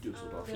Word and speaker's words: Düsseldorf: So we'll actually be traveling to Düsseldorf. Is that how you say it? I don't know. – Düsseldorf.